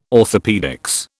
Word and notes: orthopaedics: British English

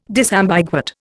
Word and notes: disambiguate: Indian

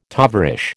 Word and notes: tovarish: American English

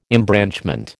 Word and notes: embranchment: American English

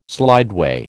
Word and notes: slideway: American English

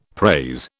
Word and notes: praise: British English

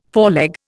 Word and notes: foreleg: Indian

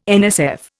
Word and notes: NSF: Indian

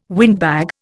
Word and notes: windbag: Indian

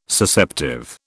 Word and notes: Susceptive: British English

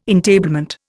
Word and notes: entablement: Indian

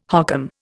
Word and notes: hokum: Indian

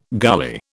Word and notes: gully: British English

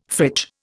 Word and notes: fridge: Indian

